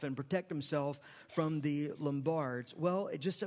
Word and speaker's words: Lombards: Say Lombards. I think that's the right way to say it. That's the way I say it anyway.